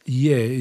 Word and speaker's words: Ye: Xiaogang Ye is the last name. The surname is Y-E, Ye.